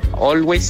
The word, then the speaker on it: Always: Quería pedir la canción de Bon Jovi, o Always, si así se pronuncia.